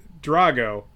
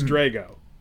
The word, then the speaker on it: Drago: I'm sorry, the trailer tells me I've been saying his name wrong my whole life. I've been saying Drago, but apparently it's Drago. According to the trailer.